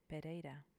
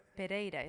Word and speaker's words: Pereira: No, Diana is simple, but people get fuzzled with the Pereira and they go into a brr, brr, brr, and I don't hear that. So I like to say just Pereira.